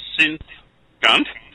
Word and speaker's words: Sinthgunt: Synth Gunt. It's SINTHGUNT. Okay, no S in there anywhere, except for that at the start, obviously.